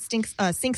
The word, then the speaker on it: Cynk: Yes, it's called Sync, or pronounced Sync.